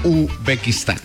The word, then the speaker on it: Uzbekistán: Uzbekistán.